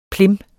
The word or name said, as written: plim